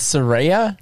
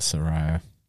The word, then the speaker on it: Saraya: It's Saraya? – Yeah. – I thought I thought it was Soraya.